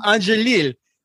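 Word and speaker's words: Angélil: Angelil. – So I think Rene Angelil, you know, who was very protective.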